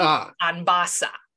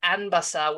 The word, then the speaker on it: Anbasa: And I had been pronouncing it the Spanish way, which would be Anbasa. – Right. – And that is not. It is Anbasa with the stress on the first syllable, but it's typically spelled A-N-B-A-S-A.